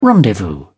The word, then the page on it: rendezvous: British English